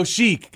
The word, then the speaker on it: Chic: But Sheik is spelled C-H-I-C, Iron Sheik. – Oh, Sheik.